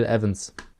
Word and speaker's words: Evans: Bill Evans. – Zumindest wird er halt im Intro verwendet. – Dennis, es wird Evans ausgesprochen. – Auf jeden Fall. 2-2-1-2.